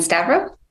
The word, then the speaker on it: Stavro: And Stavro? And if I'm saying your name incorrectly, correct me. – You're saying it good.